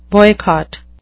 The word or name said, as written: boycott